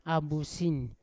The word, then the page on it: abusin: Yawa